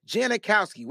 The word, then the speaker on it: Janikowski: Janikowski. That's how you say his name. Janikowski.